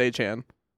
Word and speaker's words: Achane: Are we pronouncing that right? – No, it's Achane.